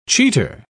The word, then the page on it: cheater: British English